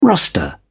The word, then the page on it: roster: British English